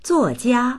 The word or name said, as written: zuo4jia1